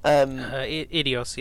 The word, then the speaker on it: Eidos: Yeah, and this for the Guardians game, it's the same company as like Deus Ex Machina and is it Eidos? Is that how you say it? – Eidos, yeah.